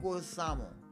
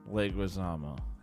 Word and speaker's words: Leguizamo: I don't know how to say his last name. Leguizamo. – Leguizamo.